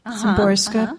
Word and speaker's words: Szymborska: Now, I don't know tell me how to say her name. Witsawa Szymborska. – Szymborska.